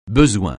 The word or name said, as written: besoin